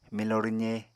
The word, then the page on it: milo rwze: Apatani